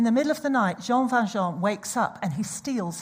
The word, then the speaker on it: Jean Valjean: So it's a musical, and there's a story about this thief called Jean Valjean. It's French. So you have to say it like that, Jean Valjean.